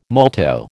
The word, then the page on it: molto: American English